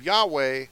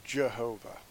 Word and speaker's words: Yahweh: Not Jehovah for the J, Jehovah. No. If you look that up, it's Yehovah. They took the vowel points of the Masoretes for Adonai, put them underneath the name of Yahweh, and started pronouncing it Jehovah. Yehovah. That's not the name. The name is Yahweh.